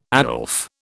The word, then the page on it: Aslef: British English